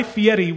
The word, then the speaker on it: Fieri: It's Guy Fieri.